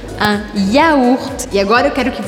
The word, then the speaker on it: yaourt: Então em francês a gente não fala iogurte, yogurt, nada disso. A gente fala yaourt. Yaourt. Pense em engolir se você quiser pronunciar o G, porque não tem G nessa palavra.